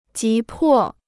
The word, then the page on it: 急迫: jí pò